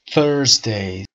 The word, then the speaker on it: Thursday: Thursday. صدای ث رو داریم. ث. Thursday.